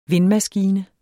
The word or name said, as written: vindmaskine